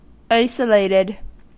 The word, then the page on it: isolated: American English